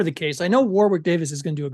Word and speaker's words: Warwick: It's pronounced Warwick. Warwick Davis.